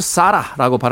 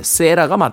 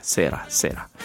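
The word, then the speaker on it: Sara: 저는 예전에 이 곡을 사라라고 발음했는데, 예, 세라가 맞다라고 합니다. 네, 세라, 세라.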